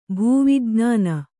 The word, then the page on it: ಭೂವಿಜ್ಞಾನಿ: bhū vijñāni